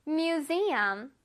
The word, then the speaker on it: museum: 자, 여기는 s 이 부분을 뚜렷하게 발음을 해줘야 돼. mu하고 z 부분을 해보자. museum. 시작. museum. museum. 잘했습니다. 우리 뮤지엄이라 그러잖아. 근데 영어로도 뮤지엄 이렇게 발음하지 않고 뮤지엄 할 거야. z 발음을 내준다고 생각해야 돼. z.